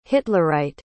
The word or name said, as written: hitlerite